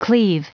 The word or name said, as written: cleave